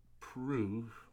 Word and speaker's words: Preux: Uh, next up, Ovince St. Preux, last name P R E U X.